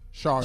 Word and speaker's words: Sharjah: Shajar. Sharjah. Shajar. Sharjah. Sharjah. Yeah, I mispronounced Sharjah.